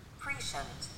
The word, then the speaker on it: prescient: Or prescient. Oh, I don't like that. – Prescient. – Prescient. – Prescient. – Or prescient. Well, it's three ways of pronouncing it. – Because the SC makes a shh noise.